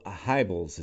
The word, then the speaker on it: Hybels: I'm wondering how, as I understand, there are like six lessons that make up the KM program, but how did Bill Hybels, is that how you pronounce his last name? – Mm-hmm.